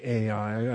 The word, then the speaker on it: Ai: And I realize the better pronunciation is simply I, but 54 years of calling it Ai, I don't know that I'm going to overcome that anytime soon.